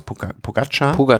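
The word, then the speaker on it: Pogačar: dahinter Pogacar, wie spricht man aus? – Pogacar.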